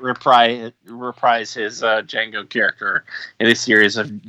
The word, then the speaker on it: Django: He's like, Django. And then Fox is like, the D is silent.